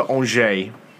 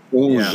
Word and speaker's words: Angers: I think it's Angers. – Andiers? Our resident European needs to brush up on his French, I guess. Basically, we sold him back to a French first division team. – We sold him to Angers. – Angers. Yeah, that's apparently how you pronounce that.